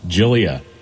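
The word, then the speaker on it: Gyliah: Or is that Jalia? It's G-Y-L-I-A-H. Help? – Jalia. – Jalia? Jalia? – Yeah. – G-Y-L-I-H.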